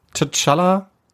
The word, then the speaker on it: T'Challa: Wir haben, äh, kann ich nicht aussprechen, als Star-Lord von What If. T'Challa. Oh. Wie bitte? Ja. T'Challa. – Okay. T'Challa. Ja, danke.